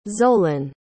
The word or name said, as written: zoelyn